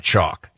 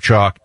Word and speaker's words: Choq: You really need it from our friends at Chalk. Chalk makes incredible supplements. It's spelled C-H-O-Q.